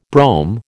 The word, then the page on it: brome: American English